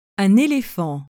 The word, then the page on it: un elephant: acute accent